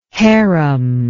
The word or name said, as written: haram